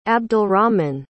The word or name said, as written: abdalrahman